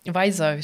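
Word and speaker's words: Pfizer: Ähm, Weiser, so spricht man das aus, oder? – Ja.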